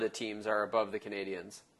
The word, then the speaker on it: Canadiens: the Montreal Canadiens, in the Atlantic.